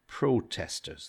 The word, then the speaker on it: protesters: Protesters, those people protesting, different stress, protesters, okay?